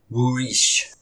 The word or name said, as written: Worish!